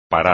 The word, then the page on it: parar: espagnol